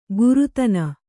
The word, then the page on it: ಗುರುತನ: gurutana